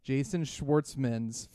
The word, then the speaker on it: Jason Schwartzman: This is Jason Schwartz. I can't even say his name. Jason Schwartzman's first movie ever.